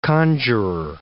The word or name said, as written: conjuror